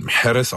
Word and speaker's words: Mahrez: Rabiot, Riyad Mahrez, der in Wirklichkeit Mahrez ausgesprochen wird, habe ich irgendwann mal gelernt.